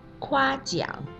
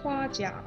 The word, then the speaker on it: 夸奖: 夸张的“ 张” 声母是 zh，卷舌发音；而夸奖的“ 奖” 是激昂奖。激在发音的时候，舌头要顶住口腔的上面，不送气发音。所以你听啊，我再念一遍：夸张，夸奖。 – 啊，我也说一遍。夸张，夸奖。